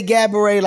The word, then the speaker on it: Gabourey: She has said, say Gabourey like cabaret.